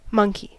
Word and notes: monkey: American English

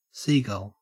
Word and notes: seagull: Australian English

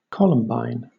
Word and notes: columbine: British English

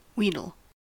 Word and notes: wheedle: American English

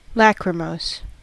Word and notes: lachrymose: American English

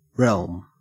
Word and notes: realm: Australian English

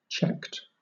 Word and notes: checked: British English

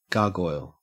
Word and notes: gargoyle: Australian English

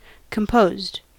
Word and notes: composed: American English

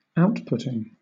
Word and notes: outputting: British English

